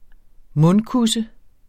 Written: mundkusse

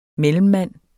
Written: MELLEMMANDEN

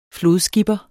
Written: flodskipper

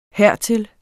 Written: hertil

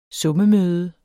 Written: summemøde

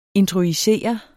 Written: introjicere

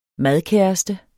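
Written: MADKÆRESTE

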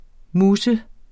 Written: MUS

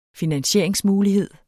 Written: finansieringsmuligheder

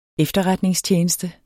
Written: efterretningstjeneste

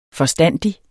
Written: forstandig

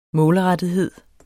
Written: målrettethed

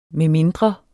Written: medmindre

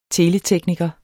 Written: teletekniker

